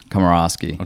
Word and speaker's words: Komorowski: because nobody can pronounce Komorowski okay.